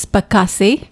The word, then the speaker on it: Pakasi: It's Pakasi. – Pakasi. What did I say? Pulaski? – Yeah. Sounds like Russian.